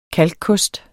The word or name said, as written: kalkkost